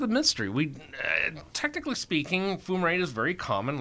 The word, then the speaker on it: fumarate: Fumarate, did I pronounce that right? – Yes.